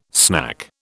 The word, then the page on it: snack: British English